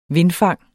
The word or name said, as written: vindfang